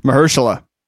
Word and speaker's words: Mahershala: How do you say his name? – Mahershala.